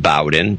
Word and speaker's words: Bowden: And I believe it's pronounced Bowden, B O W D E N.